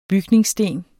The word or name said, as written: bygningssten